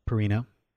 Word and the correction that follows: Parreno: Philippe Parreno.